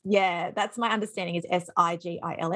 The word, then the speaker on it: sigil: So this week we're looking at sigils. So I think it can be pronounced sigil as well. Okay. But I'm just going to say sigil.